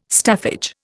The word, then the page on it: staffage: Indian